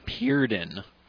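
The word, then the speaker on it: Purden: He pronounces it Purdon.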